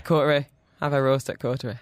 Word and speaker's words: Coterie: It is spelt, this is where the French people are like, it is coterie.